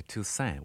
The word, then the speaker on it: Toussaint: My father used to say Toussaint without a T on the end.